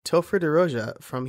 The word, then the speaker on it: Topher DeRosia: Today, my guest is Topher DeRosia from HeroPress.